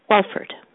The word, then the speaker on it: Walford: Walford. – Okay. Could you spell that for me? – Yes. W-A-L-F-O-R-D.